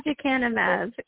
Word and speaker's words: aducanumab: Yeah, aducanumab, yep. – Oh, see, look at there. I could not even get that one out. Okay, say it again. Say the name again. – aducanumab.